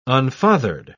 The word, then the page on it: unfathered: British English